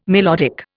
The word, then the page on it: melodic: Indian